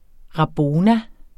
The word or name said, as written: rabona